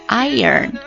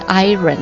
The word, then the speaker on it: iron: Iron out, iron out, 不要读成 iron 哦，它不是 iron, 它是 i-er 然后再 n, iron out, iron out。好，下面一个单词比较生僻啊， parlance, parlance, P-A-R-L-A-N-C-E, parlance, 名词表示用语、词藻或者这个说法、术语等等意思，比如说通俗的说法 common parlance。